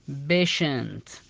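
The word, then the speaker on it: patient: ايجيبشن او كريستيان تشا تنطق بالشاء اذا اتى بعدها اي اي، او اذا اتى بعدها اي اي مثال عليها بيشنت، بيشنت هنا نطقنا حرف التي حرف الشاء لان اتى بعده اي اي، او اذا اتى بعده اي او تنطق شاء نفس الكلام، مثل كلمة اكشن، اكشن.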